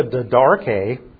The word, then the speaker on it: Didache: It's spelled D-I-D-A-C-H-E. Didache. Don't ask me why they pronounce it that way. But that's what it's pronounced as, Didache.